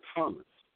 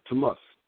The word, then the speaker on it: Tamas: And then also is a Thomas, Tamas.